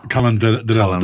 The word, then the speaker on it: Calandrella: Dakle, ja malo teško izgovaram njegovo talijansko prezime, ali Michael je, Mihael, jel? Michael Calandrella. – Calandrella, da, Marco on je sa sobom doveo i svog brata, čini mi se, Antonija Calabrellu, koji mu je prvi asistent bio.